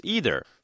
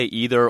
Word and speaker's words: either: Now, Grover, as it happens, isn't what he appears either. Some people say either or either. Both pronunciations are acceptable.